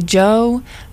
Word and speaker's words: Djo: Up next, we have a song by Joe, that's spelled D-J-O.